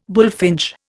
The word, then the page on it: bullfinch: Indian